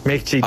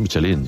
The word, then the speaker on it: Michelin: Oye, Michelin, ¿cómo se pronuncia definitivamente? – Michelin. A ver, aquí en la Península Ibérica hacemos Michelin.